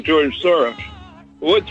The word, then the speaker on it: Soros: In Hungarian, we pronounce it Soros.